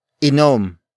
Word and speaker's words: inom: Inom. Inom, which is spelled as I-N-O-M.